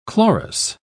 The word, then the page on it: chlorous: British English